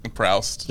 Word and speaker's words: Proust: the prod uh, the the Proust. Proust. Yeah, his, mm. that's how you pronounce his name.